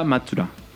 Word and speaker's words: Matsuda: ¿Matsuda o Matsuda? – Matsuda. no, es Matsuda, se pronuncia Matsuda.